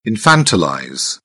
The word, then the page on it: infantilize: British English